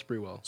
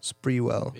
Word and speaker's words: Sprewell: Well, Latrell Sprewell. – Sprewell.